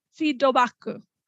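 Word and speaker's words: feedobaku (フィードバック): And so the thing is, you know, feedback, there is no Japanese word for feedback other than feedobaku. You know, feedback just pronounced with Japanese katakana pronunciation.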